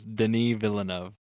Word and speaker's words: Denis Villeneuve: It's Denis Villeneuve.